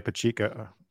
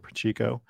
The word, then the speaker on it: Pacheco: Speaking of which, Isaiah Pacheco is the way he pronounces it. New England, we pronounce it Pacheco.